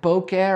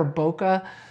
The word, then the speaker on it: bokeh: Depending on how you want to pronounce it, bokeh or bokeh, uh, bokeh comes from the Japanese word bokeh, which means blur or haze or bokeh aji, the blur quality. Uh, bokeh is pronounced bokeh or bokeh.